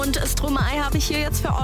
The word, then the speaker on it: Stromae: Aber ich habe zwei Eselsbrücken bei Stromae. Denken wir aber jetzt an Stroh im Mai. Stromae, Stromae, können wir uns merken.